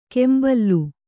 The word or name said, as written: ಕೆಂಬಲ್ಲು